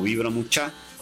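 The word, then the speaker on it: Vibra Muchá: y en las plataformas de streaming estamos igual como Vibra Mucha, con acento en la última A, y ahí van a poder encontrar la música que nosotros realizamos desde hace casi 20 años atrás, ¿sí? – Nada más y nada menos, ¿no?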